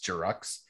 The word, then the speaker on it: Giroux: sure it's pronounced Jirux.